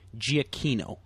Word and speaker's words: Giacchino: I think I saw him in an interview pronounce his name Giacchino. – Giacchino. – Like the exact opposite of what we've been doing.